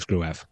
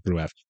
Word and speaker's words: Gruev: and the Bundesliga commentators pronounced it as Gruev. – Right. – And I also watched some random German YouTuber just for about a few minutes just to hear him pronounce the name as well, and he also said Gruev.